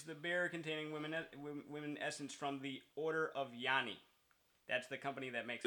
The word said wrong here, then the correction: Yoni: it's Yoni, now, as Yoni. I pronounce.